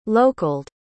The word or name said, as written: localed